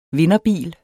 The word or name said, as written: vinderbil